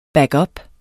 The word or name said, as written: backup